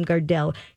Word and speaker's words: Gardell: And Billy, I think you pronounce his name Gardell.